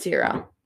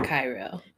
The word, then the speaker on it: Ciro: Ellen's father owns I hope it's pronounced Ciro. Ciro? C-I-R-O? I think it's Ciro. – Not Cairo.